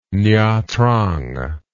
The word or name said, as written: nha trang